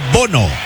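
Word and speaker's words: Bounou: Se escribe Bono. – ¿Sí? Sí. – Pero me dicen que se pronuncia Bono.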